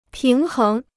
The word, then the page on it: 平衡: píng héng